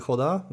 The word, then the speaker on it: roda: Z portugalskiego R jak H się wymawia? Mniej więcej. Tak, tak, znaczy wszędzie masz rację, tylko jak powiedziałbyś roda, też byś się nie mylił, ponieważ okay, w różnych częściach w Brazylii troszeczkę inne są odmiany, inaczej okay mówią.